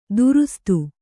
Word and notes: ದುರುಸ್ತು: durustu

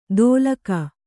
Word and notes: ದೋಲಕ: dōlaka

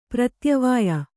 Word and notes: ಪ್ರತ್ಯವಾಯ: pratyavāya

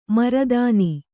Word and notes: ಮರುದಿನ: maru dina

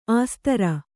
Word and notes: ಆಸ್ತರ: āstara

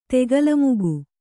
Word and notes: ತೆಗೆಲಮುಗು: kannada

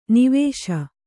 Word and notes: ನೇವಸ: nēvasa

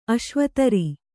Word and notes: ಅಶ್ವತರಿ: kannada; aśvatari

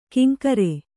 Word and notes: ಕಿಂಕರೆ: Kannada

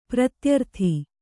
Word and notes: ಪ್ರತ್ಯರ್ಥಿ: pratyarthi

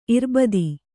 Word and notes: ಇರ್ಬದಿ: Kannada

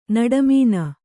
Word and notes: ನಡುಮಣ್ಣು: naḍu maṇṇu

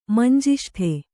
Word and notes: ಮಂಜಿಷ್ಟೆ: Kannada

